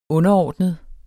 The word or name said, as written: underordnet